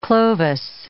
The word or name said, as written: Clovis